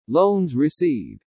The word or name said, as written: LOANS RECEIVED